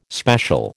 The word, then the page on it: special: American English